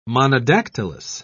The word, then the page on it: monodactylous: British English